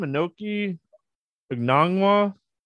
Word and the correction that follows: Okongwu: I know I totally butchered his name, and I'm sorry, but... – Okongwu? You talking about Okongwu? – Yeah, that's...